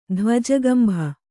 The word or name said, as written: ಧ್ವಜಗಂಭ